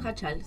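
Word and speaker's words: Jáchal: Bueno, Jachal, sí.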